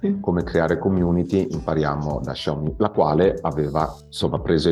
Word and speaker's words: Xiaomi: La vera pronuncia è Xiaomi o Xiaomi, perché tu l'hai pronunciato in maniera diversa da come l'ho pronunciato io. – E la pronuncia vera è Xiaomi. Xiaomi vuol dire piccolo chicco di riso, letteralmente.